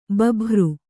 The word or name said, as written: ಭೂಭಾರ